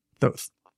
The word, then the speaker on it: Thoth: According to a legend, the pieces were lost in a battle and restored by the god Thoth. Thoth. I just mispronounced it even though...